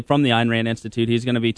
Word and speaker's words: Ayn Rand: Ayn Rand, how are you pronouncing her name? – It's Ayn Rand. – Ayn Rand.